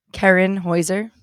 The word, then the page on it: Kerrin Heuser: female